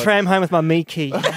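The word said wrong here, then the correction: Myki: In Victoria, we have a card called Mikey, and it's not pronounced Mickey. Thank you, for attempting that.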